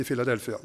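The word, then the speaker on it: Philadelphia (φιλαδελφία): Hvis dere nå synes jeg er litt fin på det, så er det fordi det er sånn det skal uttales på gresk, men det er greit, så jeg kan godt si Philadelphia.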